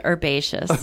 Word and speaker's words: herbaceous: Although the way that I would have pronounced it would have been herbaceous. – The correct way, yeah.